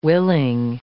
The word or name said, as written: willing